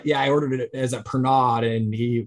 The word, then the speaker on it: Pernod: It's spelled P-E-R-N-O-D, but when we were in France, so we went to France, to Annecy we had the international premiere of Clay Dream there, and I ordered one and they called it Pernod. So I mean, I don't know like how we pronounce it here, but Greg, do you know what I'm talking about? – Yeah, I do.